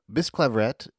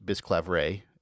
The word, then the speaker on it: Bisclavret: Bisclavret, which you'll also find pronounced Bisclavret in a modern French way, though what I've gathered, French not being my area, is that Anglo-Norman French would have pronounced that final T, and so will I.